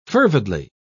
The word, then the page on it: fervidly: British English